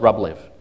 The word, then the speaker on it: Rublev: That's probably how you pronounce it, but it's Rublev. For us Aussies, Rublev.